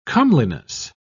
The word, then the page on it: comeliness: British English